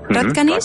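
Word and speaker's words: Hradčany: ¿Qué te parece, si para iniciar esta ruta por las antiguas calles de Praga lo hacemos desde el distrito de Radkaní? No sé si lo he dicho bien. Radkaní, sí.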